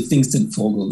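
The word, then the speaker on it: Pfingstvogel: it's actually pfingsten vogel that's that's the that's how you say it in german uh,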